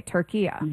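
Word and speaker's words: Türkiye: Okay, Turkiya.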